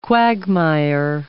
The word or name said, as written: quagmire